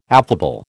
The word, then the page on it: affable: American English